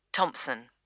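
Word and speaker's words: Thomson: That's Y-E-N-T-O-B. – Number five. – And what is your name, please? – Janet. Janet Thompson. – Is that Thompson spelled with a P? – No, it's T-H-O-M-S-O-N.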